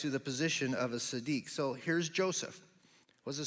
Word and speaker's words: tzaddik: In the Hebrew, it actually refers to a man who was a tzaddik, is how they would pronounce it.